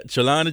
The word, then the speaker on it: Trelawney: Am I saying it correctly? – Oh, you got it, yeah. Trelawney, Jamaica.